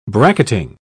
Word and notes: bracketing: British English

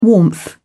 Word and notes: warmth: British English

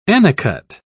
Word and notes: annicut: British English

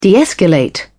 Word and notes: de-escalate: British English

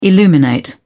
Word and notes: illuminate: British English

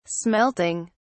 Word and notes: smelting: British English

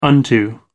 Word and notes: unto: American English

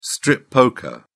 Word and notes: strip poker: British English

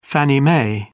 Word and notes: Fannie Mae: British English

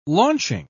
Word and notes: launching: British English

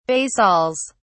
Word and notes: basils: British English

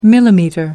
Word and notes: millimetre: American English

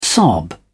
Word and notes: sob: English; British English

